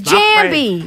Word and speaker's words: Jambi: See, you pronounced it wrong. – It's Jambi. Right.